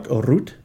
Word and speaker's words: Rutte: But anyway, the prime minister, his name is Mark Rutte.